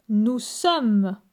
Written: Nous sommes

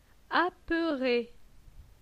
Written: Apeure

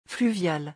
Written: fluvial

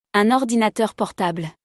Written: Un ordinateur portable

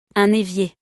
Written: Un evier

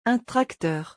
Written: Un tracteur

